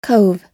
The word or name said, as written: cove